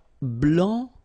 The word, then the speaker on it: blanc: Okay, so here, masculine, blanc, don't pronounce the final C, and then blanche.